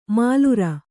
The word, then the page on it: ಮಾಲುರ: kannada